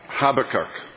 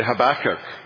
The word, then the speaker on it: Habakkuk: You say Habakkuk. No, you say Habakkuk. Right. I'm going to flip from one to the other. British emphasize the first syllable, I think, and the Americans the second syllable.